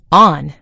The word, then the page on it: on: American English